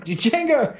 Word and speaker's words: Django: Django, the D is silent.